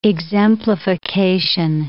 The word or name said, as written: exemplification